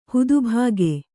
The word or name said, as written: ಹುದುಭಾಗೆ